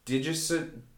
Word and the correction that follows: Digisonde: All right, somebody else pronounce this for me. Digisonde. – Digisonde. – Digisonde? An ionosphere sounding device is what it is.